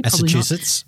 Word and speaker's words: Massachusetts: Kate Brown, an historian of environmental and nuclear history at Massachusetts, have I said that correctly? – Massachusetts.